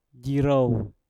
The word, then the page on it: jirouw: Mpur